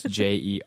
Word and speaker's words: Jermiason: It's Jermiason, and Matthew spelled with two T's.